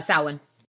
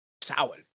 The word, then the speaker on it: Samhain: Samhain. – Samhain. That's not how I would have pronounced that. – No. It's a Gaelic word.